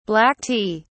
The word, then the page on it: black tea: British English